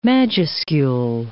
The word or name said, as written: majuscule